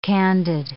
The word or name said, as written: candid